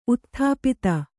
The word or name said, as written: ಉತ್ಥಾಪಿತ